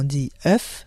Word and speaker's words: œuf: Les lettres O et E soudées sont un digramme et se prononcent E. Notez bien, au singulier, on dit œuf, mais au pluriel, on dit deux œufs.